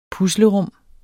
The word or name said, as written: puslerum